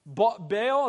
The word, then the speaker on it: Baal: Baal, as we talked about last week, Baal or Baal, depending on how you want to pronounce his name.